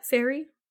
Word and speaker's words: Ferrie: So Rogers joined the Civil Air Patrol in the mid-1950s, where he allegedly encountered David Ferry, I think. It's spelled F-E-R-R-I-E. – Yeah, Ferry. – Ferry. Very, that's what I'm going to go with.